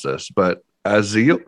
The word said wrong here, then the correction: Azeli: what is that? – Azeli. – Wow. Say that again. I totally butchered that. – Yeah, so the company name is Azeli Marketing.